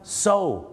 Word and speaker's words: sew: ออกเสียงไงนะครับ SEW เชิญเลยครับอะไรครับซิวเหรอครับไม่ใช่ซิวครับผมซูโอไม่ใช่นะฮะไม่ใช่ครับซูเนี่ยนะครับภาษาอังกฤษเนี่ยนะครับจะเป็นชื่อผู้หญิงหรือจะแปลว่าการฟ้องร้องคดีก็ได้ครับคำนี้ออกเสียงว่าอะไรไหมครับ so ถูกต้องแล้วครับ S-E-W ออกเสียงว่า so